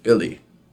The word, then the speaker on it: Belly: Belly, it's actually pronounced Billy, is the nickname of my aunt.